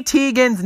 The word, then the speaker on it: Teigen: It's pronounced Teigen. Teigen, not Teigen.